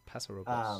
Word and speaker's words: Paso Robles: Paso Robles.